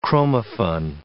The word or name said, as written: chromaffin